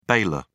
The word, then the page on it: bailor: British English